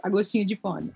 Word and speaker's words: Agostinho de Hipona: Agostinho de Pona. Falei certo, meninas? Agostinho de Hipona.